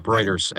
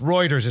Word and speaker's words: Reuters: You said it right the first time. Reuters. Absolutely. – I've had only because I've had arguments with people. So if you're listening, it's Reuters. It's not Reuters.